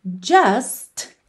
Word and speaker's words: just: Just. É uma palavra que termina em T, mas frequentemente esse T não é pronunciado. Então você ouve just because I can.